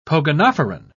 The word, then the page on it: pogonophoran: British English